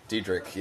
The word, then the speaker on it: Diedrich: Diedrich, yeah. It's kind of, it almost sounds like there's a K at the end.